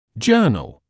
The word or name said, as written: journal